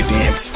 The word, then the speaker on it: DNCE: Is it pronounced dance, or is it they say all the letters? – I always hear DNCE. I've never heard anyone say the whole thing. I just say dance.